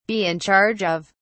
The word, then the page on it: be in charge of: British English